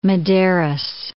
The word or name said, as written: Medeiros